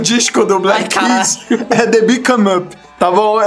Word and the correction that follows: The Big Come Up: Repete a pronúncia? – Não. Como é que é? – Não, eu não, lê aí. Lê você, pô, tá mostrando por quê. Pode ir. The Big Come Up.